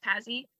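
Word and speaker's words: Pazzi: And the Patsy, or it's P-A-Z-Z-I, but I think it's pretty much just pronounced Patsy.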